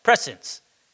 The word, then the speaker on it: prescience: but the theological word is prescience, or to know beforehand. Pre-science, if you wanted to pronounce it like that, prescience.